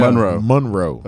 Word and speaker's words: Monroe: Even though it's spelled M-O-N, it's yeah. – Monroe.